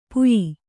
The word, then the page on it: ಪುಯಿ: puyi; Kannada